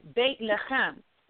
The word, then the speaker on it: Bethlehem: So in Hebrew or in the Ivrit, it is pronounced Bethlehem, which literally means house of bread.